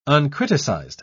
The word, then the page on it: uncriticized: British English